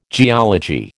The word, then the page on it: geology: American English